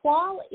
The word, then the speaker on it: toile: So first of all, tell us, what is the definition of twa, which is spelled T-O-I-L-E for our listeners?